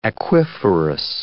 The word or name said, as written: aquiferous